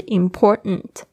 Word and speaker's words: important: 有时候呢，你会发现很多的那种美式发音呢，它是 important，它那个 t 的声音不会发出来。这个是一个 t 的发音的潜规则，大家特别注意一下。就是它后面的发音是 n 的声音的时候，它有时候会弱化，直接不念了。